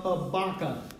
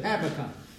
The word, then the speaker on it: Habakkuk: The book of Habakkuk. Now, some people pronounce it Habakkuk, but I pronounce it Habakkuk.